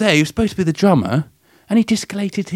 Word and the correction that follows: dislocated: his... That's not how you say it. Discolated? – Dislocated. – Dislocated his shoulder.